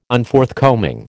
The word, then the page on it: Unforthcoming: American English